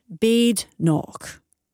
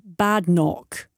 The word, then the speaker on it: Badenoch: Badenoch, which is properly pronounced Badenoch, with emphasis on the ch, as in loch, not pronounced Badenoch.